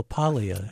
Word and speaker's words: Paglia: By the way, your name, like Camille Paglia, doesn't pronounce the G in her name. She just says Camille Paglia.